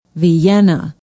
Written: vienna

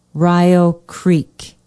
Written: rio creek

